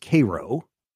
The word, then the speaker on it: Cairo: You notice right there where these two rivers are joining is a city called, it looks like it's spelled Cairo. It's pronounced Cairo.